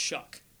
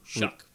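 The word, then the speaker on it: Shough: It's pronounced Shuck. – Shuck? – Shuck. Which there's no K. – Yeah, there's a GH. – It's a GH. Shuck.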